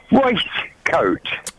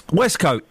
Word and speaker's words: waistcoat: It's spelt W-A-I-S-T, which pronounces waste. – Yeah. Lamb is spelt L-A-M-B, lamb. But we say lamb. The beauty the beauty of the English language is some words is not pronounced like what they are written. Westcoat is pronounced Westcoat.